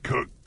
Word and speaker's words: Keurig: And I gotta say, it might damage the keyring downspout. – Keurig. – What? – Keurig.